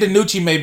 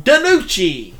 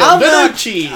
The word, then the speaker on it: DiNucci: No, you didn't say that right. Ben DiNucci. – Okay. Well, Ben DiNucci was brought back. No, you got to add the Mario deal. DiNucci.